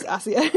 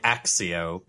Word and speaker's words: Accio: Asio or Accio. I always yeah. I prefer Asio as the as the pronunciation. – uh, I say Axio because that's how they say Axio.